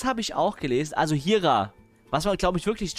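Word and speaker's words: Jira: Jaira. Genauso geschrieben, wie es ausgesprochen wird.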